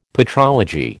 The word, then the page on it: patrology: American English